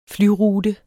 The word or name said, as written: flyrute